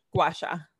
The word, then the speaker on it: gua sha: Gua sha.